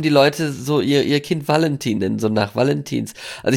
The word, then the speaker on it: Valentin: In Bayern wird der Name Valentin ausgesprochen. Mit Vogel V.